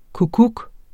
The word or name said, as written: kukkuk